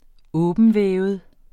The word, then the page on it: åbentvævet: [ ˈɔːbəndˌvεːvəð ]; [ -ˌvεˀvəð ]